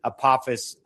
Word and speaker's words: Apophis: It's the asteroid Apophis or Apophis, but it's really Apophis, in its proper pronunciation, to come by on April 13th of 2029, and then also secondarily to come back around in 2032.